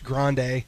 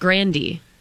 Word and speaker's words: Grande: They say it, like, in the Spanish language, grande. – Yeah. – Yeah. – But then Ariana, that's not how you'd say that. – She says it's pronounced grandy. – Yeah, so she doesn't use the Spanish dialect to do the E at the end or the different, right.